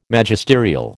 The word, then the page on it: Magisterial: American English